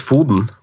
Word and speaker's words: Foden: Jeg tror du uttales Foden.